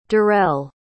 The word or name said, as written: darelle